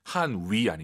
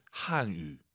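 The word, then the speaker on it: 汉语: 한위 아니고 입술 동그랗게 말아보세요. 동그랗게. 유, 한유. 한유.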